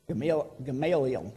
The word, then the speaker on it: Gamaliel: when he was dealing with Jesus, and then in this instance, Gamaliel, however you say his name. – Gamaliel. – Yeah, that. He warned them,